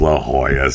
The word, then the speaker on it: La Jolla: Is it La Jolla? Is that how you pronounce that? I know it's La Jolla.